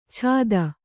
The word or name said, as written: ಛಾದ